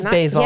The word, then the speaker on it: Basel: Have you heard of Art Basel? – No. – And I know it says Basel.